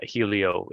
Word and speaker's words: Helio: Yeah, it's spelled Helio, so I don't blame you for thinking that. But yeah, Helio, it's named for the Greek god of the sun, Helios, actually.